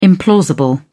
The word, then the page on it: implausible: British English